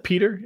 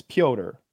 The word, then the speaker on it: Pyotr: Like, you can do that and call him Peter, but he's Russian, and it's Pyotr. And it's spelled funny as well. – P-E-T-R, I think.